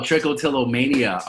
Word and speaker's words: trichotillomania: Help me pronounce this. Is it tricotillomania? – Oh, trichotillomania.